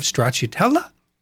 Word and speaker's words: stracciatella: Aged cheeses, and we'd have a homemade marmalade and potential some honey on that, and a bowl of stracciatella.